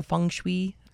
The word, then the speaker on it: feng shui: So, today we're going to talk about the elements and how they figure into feng shui.